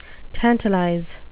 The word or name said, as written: tantalize